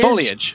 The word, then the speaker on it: foliage: The Simpsons joke about that always makes me forget the actual pronunciation. – Foliage.